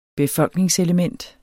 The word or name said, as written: befolkningselement